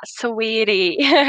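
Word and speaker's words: Saweetie: a rapper Sawiri e o australiano Sam Fisher. – Ó, oh, arrasou na pronúncia. Tentem falar sweetie.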